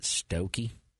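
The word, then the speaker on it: Stokoe: I believe you're going to pronounce it Stokey.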